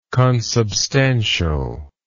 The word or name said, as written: consubstantially